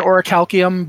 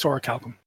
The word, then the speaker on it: Orichalcum: Some people pronounce it Orichalcum, but it's Orichalcum.